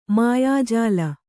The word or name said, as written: ಮಾಯಾಜಾಲ